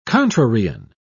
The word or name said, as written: contrarian